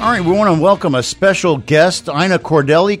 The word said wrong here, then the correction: Cordell: Cordelli, how do I say that, Ina? – You say Cordell. – Cordell. I struck out.